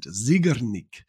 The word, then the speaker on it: Zeigarnik: I had to actually look up how to pronounce it, Zeigarnik.